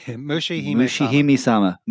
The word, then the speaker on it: Mushihime-sama: I'm going to say this wrong, Mushishimesa. – Mushihime-sama. – Mushihime-sama.